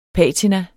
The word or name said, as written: patina